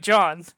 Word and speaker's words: Can: It's spelled C-A-N, but in Turkish, it's pronounced just like John.